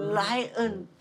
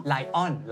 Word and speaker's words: lion: lion ถูกครับไม่ใช่ lion แล้ว